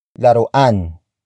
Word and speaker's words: laruan: Laruan ng aso yan. Laruan ng aso yan. The first word is laruan. Laruan. Laruan. Laruan is spelled as L-A-R-U-A-N.